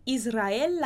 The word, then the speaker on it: Israel: Israel, esse L também é mais prolongado. Israel é um E fechado, a gente já viu sobre isso, né? Israel habla muy rápido. Mas a gente junta esse Israel habla muy rápido.